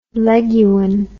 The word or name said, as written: leguan